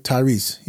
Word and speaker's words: Tyrese: Tyrese, yeah.